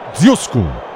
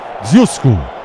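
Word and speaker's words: Ožujsko: É Dziuszko. Se escreve DZ com aquele circunflexo ao contrário, UJSKO. Dziuszko.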